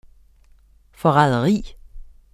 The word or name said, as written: forræderi